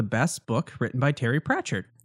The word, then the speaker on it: Pratchett: I am looking for the best book ever written by Terry Pratchett.